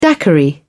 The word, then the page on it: daiquiri: British English